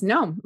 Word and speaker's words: Nome: Nome, am I saying it right? – Yes, Nome.